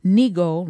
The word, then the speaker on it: Nigo: And Up Close and Polyamory, which was very interesting, with Nikki Nigo, rhymes with ego, and Chad Gutram.